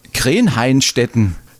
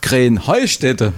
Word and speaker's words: Krähenheinstetten: Ja, ihr saget immer Krähenheinstetten und dabei heißt es doch Krähenheustätte, oder wie ist es? – Krähenheustätte. – Ja, genau, und das ist die Betonung auf dem Heu.